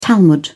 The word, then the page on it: talmud: British English